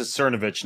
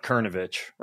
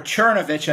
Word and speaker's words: Cernovich: You pronounce it Cernovich, not Kernovich, right, Mike? – Right, or Chernovich, I guess, is the proper way, but we go with the Anglicanization of it.